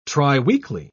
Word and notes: triweekly: British English

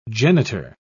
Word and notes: genitor: British English

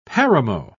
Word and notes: paramo: British English

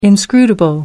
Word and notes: inscrutable: American English

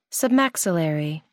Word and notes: submaxillary: American English

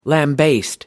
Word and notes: lambaste: American English

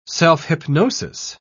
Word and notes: self-hypnosis: British English

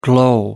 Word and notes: glow: American English

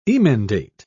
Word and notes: emendate: British English

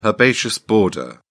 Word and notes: herbaceous border: British English

